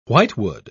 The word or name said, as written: whitewood